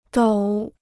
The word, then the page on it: 抖: dǒu